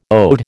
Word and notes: ode: American English

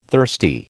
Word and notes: thirsty: American English

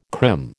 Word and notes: crim: American English

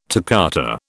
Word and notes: toccata: British English

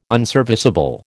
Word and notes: unserviceable: American English

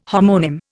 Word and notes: homonym: Indian

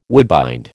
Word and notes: woodbind: American English

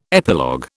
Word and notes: epilogue: British English

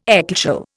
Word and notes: ecclesial: Indian

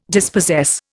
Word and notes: dispossess: Indian

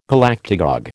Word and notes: Galactagogue: American English

